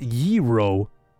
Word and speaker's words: gyro: Ipid Sushi says, technically it's pronounced Yiro. Then why the fuck is it spelled G-Y-R-O if it's pronounced Yiro?